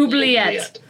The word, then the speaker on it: Oubliette: Wanted for theft, smuggling, aiding a prisoner, and escape from the Obsidian Obulate. I, I, I hope I pronounced that correctly. Oubliette. Yeah. Oubliette. – the Obsidian Oubliette.